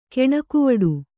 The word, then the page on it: ಕೆಣಕುವಡು: keṇakuvaḍu